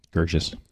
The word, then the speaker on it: Gerges: This one is Melina Gerges. – Gerges. – Gerges? – That's how I would pronounce it. – Okay. It's spelled G-E-R-G-E-S.